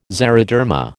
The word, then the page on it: xeroderma: American English